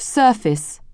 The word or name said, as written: surface